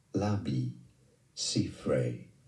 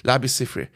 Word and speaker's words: Labi Siffre: Im Pronunciation Labissifrey. – Ja, okay, ich habe schon richtig gesagt. Labissifrey.